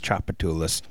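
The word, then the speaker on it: Tchoupitoulas: I don't remember how I pronounced Chapatulas in New Orleans wrong, but I remember I said it wrong on the radio, and someone rushed into the studio and was like, it's Chapatulas. Learn how to pronounce things.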